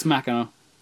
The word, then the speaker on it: Machina: Is it pronounced Machina or is it Machina? – It's Machina. – Is it really? – Yeah. – I never knew that. – Have you been calling it Machina? – I have been calling it Machina, yeah. Why wouldn't you? That's how it's spelled.